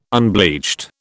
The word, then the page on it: unbleached: British English